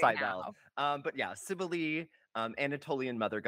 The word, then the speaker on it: Cybele: And speaking of things that are, you know, questionably pronounced, I'm actually talking about Cybele today. Maybe sometimes Cybele. One person said Cybele. I don't like that one.